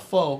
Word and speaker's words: pho: I thought it was called pho, by the way, because it's spelled P-H-O. You know, so I asked the lady. I was like, I'll have a bowl of pho. And she's like, no, it's pho.